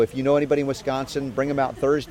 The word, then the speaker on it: Wisconsin: Wisconsin? – You did.